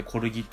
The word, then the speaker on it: 骨気: コルギメソッドっていうのは、なんか、コルギ療法のベースとなったコルギ法、まあ、骨の木って書いてコルギって読む